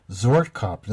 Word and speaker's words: Zwartkop: Zwartkop, yes, is the name. I've also heard it described as, or called zwartkop, but zwartkop is the original Dutch name of the cultivar.